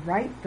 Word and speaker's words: write: When you write the word, don't forget the silent consonant. Rule 1. W is silent before R at the beginning of a word.